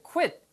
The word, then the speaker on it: quit: 이 단어가 quit이 아니고요. quit이에요. Q 다음에 U가 오잖아요. 그러면 영어 사전을 찾아보시면은요. 여기에 W 발음이 있습니다. 90% 이상. 그래서 quit, quit, quit 이렇게 입술이 나갔다고 들어오면서 하시면 돼요.